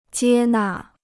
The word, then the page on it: 接纳: jiē nà